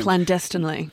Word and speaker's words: clandestinely: Not so much walking and talking, because they're meeting clandestinely in hotel rooms. – Clandestinely. – That's the word. That's how you say it. Good pronunciation there.